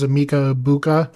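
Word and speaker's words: Emeka Egbuka: Now, we also saw last week with the Buccaneers, Emeka Ebuka. I think that's how you say his name. Yep.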